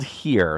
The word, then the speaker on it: Hir: The play is called Here, spelled H-I-R, but it's pronounced Here, H-E-R-E.